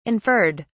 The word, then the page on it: inferred: British English